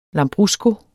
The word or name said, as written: lambrusco